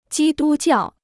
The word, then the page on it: 基督教: jī dū jiào